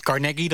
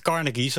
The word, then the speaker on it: Carnegie: Ja, en je zegt Carnegie, dat is dan waarschijnlijk de officiële manier waarop je dat moet uitspreken. En ik heb altijd gezegd Carnegie, zoals heel veel Hagenaars, maar bij deze weet ik dan hoe je dat dus moet zeggen.